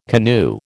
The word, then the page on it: canoe: American English